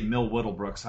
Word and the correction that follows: Will Middlebrooks: I always say his name wrong. Will Middlebrooks is supposed to be back by this weekend.